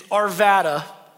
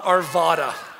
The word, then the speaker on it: Arvada: Okay, like it's Arvada, not Arvada, all right? You wouldn't say Nevada. You would say Nevada, all right?